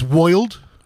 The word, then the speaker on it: world: And then, and then they had an actual 1892 wax cylinder recording of Walt Whitman reciting four line poems, which he would pronounce like the word, world as woiled.